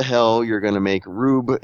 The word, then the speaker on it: Rube: that maybe closing the chest stopped a rude Goldberg type. – A rude? He's rude? – Rube. – Okay. – Rube Goldberg.